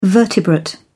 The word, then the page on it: vertebrate: British English